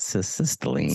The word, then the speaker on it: cytisinicline: So can you describe how does cytisinicline, is that how to say it? Cytisinicline. – Cytisinicline. – Cytisinicline. Yeah, see, that's why I'm not a pharmacist.